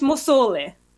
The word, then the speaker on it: Musolli: It's meant to be two L's, not three L's. But it's Musolli.